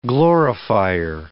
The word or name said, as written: glorifier